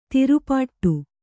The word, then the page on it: ತಿರುಪಾಟ್ಟು: kannada